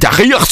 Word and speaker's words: Tahrir: Remember, if you want to be taken seriously in journalism, you got to pronounce it right. So it's Mubarak and Tahrir Square. Tahrir Square.